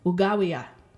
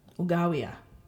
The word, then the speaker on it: ugawiya: Okay, and if you want to talk about his or her mother, again, it sounds different. Ugawiya, ugawiya. Okay, the emphasis is on a different syllable there.